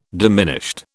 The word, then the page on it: diminished: British English